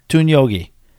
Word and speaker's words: Tunyogi: Tunyogi. No, you're putting the emphasis in the wrong syllable.